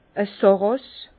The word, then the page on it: e Sàrràs: Bas-Rhin; Herrlisheim